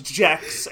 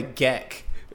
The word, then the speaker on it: Gek: excuse me, it's pronounced Jex, and it's about a dinosaur. – No, the Gek, with a K.